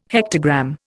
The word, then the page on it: hectogram: Indian